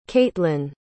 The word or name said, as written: kaitlynn